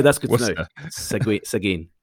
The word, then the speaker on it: Seguin: It's Seguin. – Oh, love your show. So thanks for loving the show. Sorry for us not being able to pronounce Seguin, but that's Seguin.